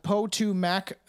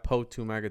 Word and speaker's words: Potomac: The Potomac to the former Civil War battlefields? – Potomac. The Potomac. You don't say it like that, Potomac.